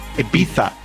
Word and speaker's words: Ibiza: Today's the last day of Ibiza Gay Pride, and you have to say it like that. Ibiza.